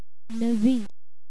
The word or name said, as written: navi